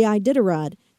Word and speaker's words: Iditarod: One of the most interesting thing for me was the Iditarod. Did I pronounce that correctly? – You did, and this was our number three trend this week.